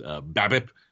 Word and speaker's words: BABIP: Also with his BABIP, not a silent P there.